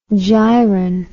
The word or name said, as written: giron